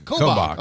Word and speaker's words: Kobach: Kobach.